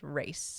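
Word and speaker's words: Reiss: Like, say Rice again? Race? – Yeah. – Wait, again, is it Race or Rice? – We're going to use it interchangeably. – Yeah, I'm pretty sure... The correct pronunciation is Race.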